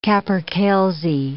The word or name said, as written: capercailzie